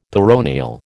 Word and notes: peroneal: American English

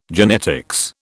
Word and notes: genetics: British English

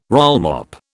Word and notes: rollmop: British English

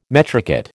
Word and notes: metricate: American English

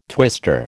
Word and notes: twister: American English

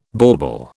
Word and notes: bauble: British English

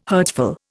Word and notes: hurtful: Indian

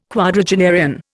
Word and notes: quadragenarian: Indian

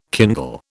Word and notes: kindle: British English

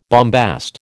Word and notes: bombast: American English

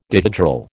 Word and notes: dihedral: American English

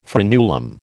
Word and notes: frenulum: American English

